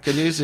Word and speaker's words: Cadus: So it's Tonellerie, which is T-O-N-N-E-L-L-E-R-I-E. Spell that. Caduce is C-A-D-U-S .com.